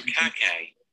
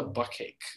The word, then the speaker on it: Bukkake: Like, watching, like, being a kid, you don't think, like, there's different ways of pronouncing words, so, like, you're just seeing it phonetically, so you're just like, what's a buckcake?